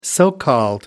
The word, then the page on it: so called: American English